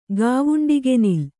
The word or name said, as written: ಗಾವುಂಡಿಗೆನಿಲ್